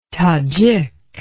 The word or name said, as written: tadjik